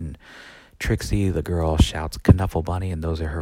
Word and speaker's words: Knuffle: and he has a book called Knuffle Bunny, um, or Nuffle Bunny. You could pronounce it with a silent K.